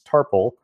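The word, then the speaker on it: Tarfful: I was mispronouncing it in my head as Captain Tarful, but it's Tarful.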